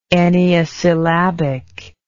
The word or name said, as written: enneasyllabic